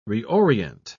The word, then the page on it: reorient: British English